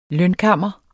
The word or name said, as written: lønkammer